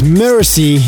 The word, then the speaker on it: MRZY: His name is Mercy, you spell it M-R-Z-Y with Bring It. Sick tune, and keep an eye on M-R-Z-Y, Mercy, amazing artist.